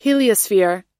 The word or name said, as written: heliosphere